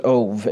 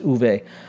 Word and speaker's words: Ove: The book is A Man Called Uwe, or you might pronounce it Ove, if you O-V-E. The author is Swedish. He's from Sweden, and so I believe that would be pronounced Uwe.